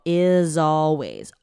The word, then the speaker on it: is always: 这边全部都是 z 的音哦 Is always.